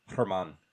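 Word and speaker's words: Germán: They got rid of a real prospect in Frank Germain, or German, I don't know how to say it. Herman.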